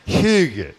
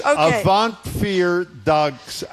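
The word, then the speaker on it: Avondvierdaagse: Okay, I believe it's pronounced Hugge is the name of the... – Hugge. – No, it's not, actually. It is Avant Vier Dags.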